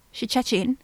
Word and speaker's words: Szczecin: He tells his dad that he's close to a town called Szczecin, which I think is how it's pronounced. That's what Google told me it's pronounced as Szczecin.